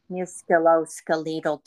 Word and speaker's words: musculoskeletal: Now, how do you say musculoskeletal disorders? – I think you nailed it.